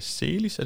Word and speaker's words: Celis: Og øh, med os i dag, der har vi øh, Søren Severin fra BSF og Rolf Snækkerup fra øh, Celis. Er det sådan, det udtales? – Ja.